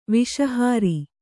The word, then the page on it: ವಸುಹರ: vasu hara